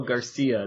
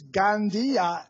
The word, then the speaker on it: Gandia: It's not Garcia. It's Gandia.